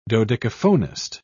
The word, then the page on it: dodecaphonist: British English